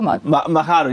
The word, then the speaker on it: Machado: Frau Machado? – Machado, ja.